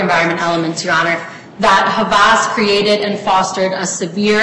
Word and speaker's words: Havas: Okay, let's say that you hadn't settled with, and is it pronounced Havas? – Havas.